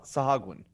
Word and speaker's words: Sahuagin: I never say it right. Sahuigin? – Sahagwin. – Sahagwin? – Yes.